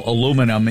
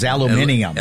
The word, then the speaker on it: aluminium: and they pronounced what we would call aluminum. It was aluminium.